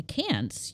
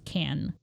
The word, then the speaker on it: Cannes: Cannes, you know? It's actually pronounced Can.